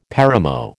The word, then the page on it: paramo: American English